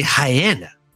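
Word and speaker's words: Rayanne: So the first things first, I got to make a vast apology here and say that I significantly mispronounced this lady that I said Ryan or Rian Dos Santos. Her name is actually Hyena, which is way cooler. Like, sounds like hyena.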